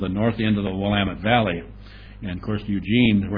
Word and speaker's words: Willamette: But I live in the north end of the Willamette Valley, pronounced Willamette.